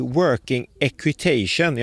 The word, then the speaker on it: working equitation: För det ska ju tävlas då SM i working equitation. Jag har försökt öva på det där uttalet. – Ja, men du satte det rätt bra faktiskt.